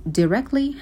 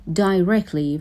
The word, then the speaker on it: directly: کلمه directly دوتا تلفظ داره، هم میتونیم بگیم directly هم بگیم directly و هر دوتاش هم کاملا درست هستش.